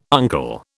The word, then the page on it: uncle: British English